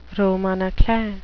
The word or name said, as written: roman a clef